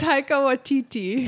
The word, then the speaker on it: Taika Waititi: It was Taika Waititi. Well, how do you say it? I can't ever say it. – Taika Waititi.